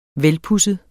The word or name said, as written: velpudset